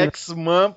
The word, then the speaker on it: X-Men: Engraçado você ter que falar X-Men, porque se você falar com a pronúncia certa, que é X-Men, só que é com A, ninguém entende, né?